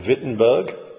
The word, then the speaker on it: Wittenberg: Martin Luther, a monk, an Augustinian monk, in an East German town, a university town called Wittenberg. That's spelt with a W, not with a V. That's just how the Germans pronounce their W's.